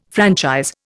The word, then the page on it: franchise: Indian